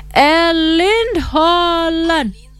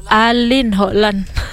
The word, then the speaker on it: Erling Haaland: Sempat nyari tahu soalnya cara bacanya pakai bahasa Norwegia. Sebentar ya teman Fox lagi bingung. Erling Haaland. Tuh kayak gini teman Fox. Erling Haaland.